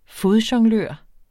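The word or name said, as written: fodjonglør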